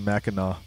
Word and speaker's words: Mackinac: It's actually Mackinac. – Well, why is there a C at the end? – I know. I said the same thing. But it's pronounced Mackinac Island. It's Native American. It's Native American, I think.